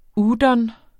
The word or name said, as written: udon